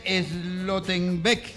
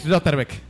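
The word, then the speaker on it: Schlotterbeck: Aquí dice Slottenbeck. – Es Lotterbeck. – Bueno, Lotterbeck. Muy bien.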